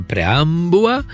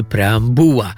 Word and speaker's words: preambuła: Z preambułą już jest raczej tak, że mało kto powie preambuła, i wtedy byśmy uważali, że tam jest tylko jakieś u, niezgłoskotwórcze preambuła. Natomiast mówimy preambuła z akcentem na u i to się upowszechniło tak bardzo, że dzisiaj jest to uważane za normalną jedyną wymowę.